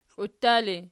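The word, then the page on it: kliuttali: Archi; nominative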